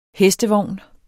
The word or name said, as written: hestevogn